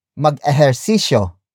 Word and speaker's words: mag-ehersisyo: For example, mag-ehersisyo, Mag-ehersisyo. Mag-ehersisyo. Mag-ehersisyo is spelled as M-A-G hyphen E-H-E-R-S-I-S-Y-O. It means to exercise or a command to do a physical exercise.